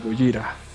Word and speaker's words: Gojira: Pero ya fue creo que hasta cuando soltaron el álbum, que decidieron cambiarse por el nombre como lo pronuncian ahí en la película de Hollywood, la del noventa y tantos, el japonesito Gojira.